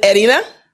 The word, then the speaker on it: Erina: Is it Erina? – Erina, yes. – Erina, excellent. – That's correct. Erina.